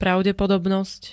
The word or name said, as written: pravdepodobnosť